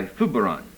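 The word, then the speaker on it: Zurbarán: It's by Zubiran. That's how you pronounce it. It's a Basque name, so the Z is pronounced TH, Z-U-B-A-R-N, and it's called Luke the Artist.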